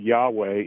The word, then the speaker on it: Yahweh: It's fascinating that as you trace the use of the name of the Lord, Yahweh is the best way we can pronounce the four consonants that were given in Hebrew.